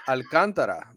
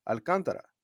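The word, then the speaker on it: Alcántara: Alcantara. That's the way you say it in Spanish, right? I mean, that's the real pronunciation. Alcantara. – Right, because you're pronouncing the A.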